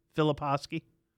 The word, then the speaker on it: Filipowski: David, my producer, is it Filipowski? Do you know Kyle Filipowski, the basketball player? Yeah, that's how you pronounce it, Filipowski.